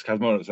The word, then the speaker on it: Cosmatos: Yes, Cosmatos, that's how you say it.